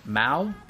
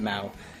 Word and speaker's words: Mao: Now, this version is vocalized version from the live concert version of this album, and I think her name is pronounced Mao, M-A-O, and...